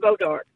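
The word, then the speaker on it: bois d'arc: Did you know the name comes from Boaz to Ark? – Yes, I've seen it in writing even when I was a kid in Texas, but we pronounced it Bodark.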